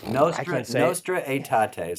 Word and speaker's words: Nostra Aetate: It was called Nostra Aetate, is how they say it.